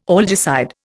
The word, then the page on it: algicide: Indian